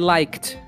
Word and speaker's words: liked: É claro que a pronúncia é assim, ó, I liked, como se fosse um T.